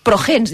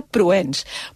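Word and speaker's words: Prohens: L'altre dia la meva filla petita em diu, Progens, dic, no, Martina, no, Progens no. És Marga Proens.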